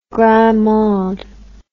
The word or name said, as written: grand monde